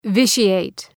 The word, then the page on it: vitiate: ‘vıʃı,eıt